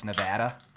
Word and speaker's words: Nevada: We learned that you don't call it Nevada, it's Nevada.